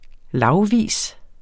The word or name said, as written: lagvis